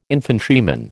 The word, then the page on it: infantryman: American English